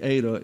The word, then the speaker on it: eta: That's an eta there, so it has a, when you pronounce the eta, it's like a long A.